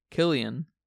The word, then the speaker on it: Cillian: Cillian? Cillian Murphy? – Yeah, they're British. Cillian and Christopher Nolan. – Uh-huh. – So they say Killian.